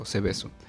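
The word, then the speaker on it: Seveso: Este segundo caso se trata, o más bien es llamado Cebeso, con S. C-E-V-E-S-O, Cebeso. Y es que Cebeso, Cebeso, perdón, con acento en la E, primera E.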